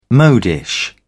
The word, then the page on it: modish: British English